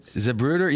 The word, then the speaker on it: Zapruder: It's like the Zapruder film. How do you say that? – Zapruder, yeah.